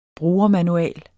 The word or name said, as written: brugermanual